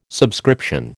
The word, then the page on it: subscription: American English